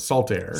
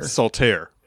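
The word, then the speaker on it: Saltair: That's one word, Salt Air. – Salt Air. Okay. – Not salt. It's spelled like salt air.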